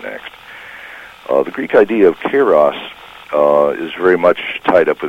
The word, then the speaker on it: Kairos: I'm talking about Kairos, is that pronounced correctly? And Kronos? – Yeah, Kairos and Kronos.